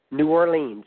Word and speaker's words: New Orleans: New Orleans, that's how we pronounced it.